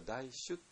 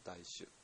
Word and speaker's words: 大衆: れは主に従うっていうね言葉を先週随文経の中で出てきて、主っていうのはね、これは大主って言い方、これ現代の日本語だと大衆ってなっちゃってね、大きな州ですね。州は衆議院の州ですよ。で大衆というと、まあ、なんか一般世間という意味になっちゃいますけども、禅宗の場合のだとそれは大衆って発音します。大衆、ね、大衆って発音して、それは、修行仲間っていう、意味合いになります。大衆がとかね、いろんな言い方をして、これテラバだとナンダジビクっていうね、とほぼ同じ意味合いですね。